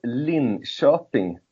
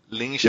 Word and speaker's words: Linköping: It's called Linköping University. – Linköping.